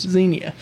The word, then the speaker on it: Xenia: And you guys have just come off of the Buckeye Nationals up in Xenia, Ohio. I'm saying that right? – Yes. – Xenia, Ohio.